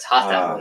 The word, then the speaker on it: Jota: J-O-T-A. – It's spelled the same, but it's Hata, not Jota.